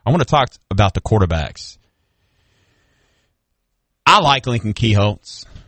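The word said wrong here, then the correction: Kienholz: How do you say it? – Kingholtz. Kingholtz, I believe. Yeah. – Kinko's? – No. – Like the Staples? Knockoff Staples? It sounded like you said Kinko's.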